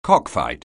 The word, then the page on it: cockfight: anglais